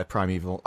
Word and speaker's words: Primeval: Pena for $2 says, I grew up watching a British show called Primeval. Is that it? Did I say that right? – I've heard... Yeah, Primeval.